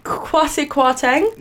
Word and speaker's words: Kwasi Kwarteng: Right, I'm pretty sure I'm going to say his name on Kwasi Kwarteng.